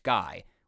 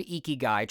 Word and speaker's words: ikigai: The term is composed of two Japanese words, iki, which means life or alive, and kai, sometimes pronounced gai, which can mean many things, including effect, result, fruit of, worth from, or even use for.